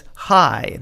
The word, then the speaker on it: Haai: goes as Hi. That's spelled H-A-A-I.